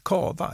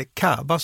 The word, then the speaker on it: cava: Vi börjar i Spanien med cava, eller cava som det också uttalas.